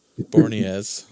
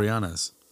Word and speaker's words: Boreanaz: David Boreanaz guy too. – Boreanaz. – Yeah. – Boreanaz.